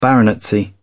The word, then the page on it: baronetcy: British English